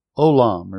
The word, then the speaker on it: Olam: But the hesed of the Lord is from Olam. Remember that from last week? O-W-L-A-M is how it's spelled, and we just pronounce O-L-A-M.